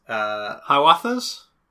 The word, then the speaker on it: Hiawatha's: Hiawatha's? – Yeah.